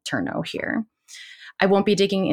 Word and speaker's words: terno: Now, you've heard me talk about the terno before, or terno in with the Filipino pronunciation.